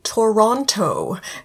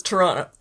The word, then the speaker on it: Toronto: If any Americans are confused, we're talking about Toronto, as you might pronounce it. But to most Canadians, it's Toronto.